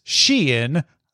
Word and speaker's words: Shein: It seems the pronunciation of said brand swings back and forth. But if the Times says Shein, that's what I'm going with.